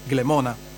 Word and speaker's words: Glemona: che in Furlan si pronuncia come in latin, Glemona, e anche le sue autonomie.